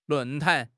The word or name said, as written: 论坛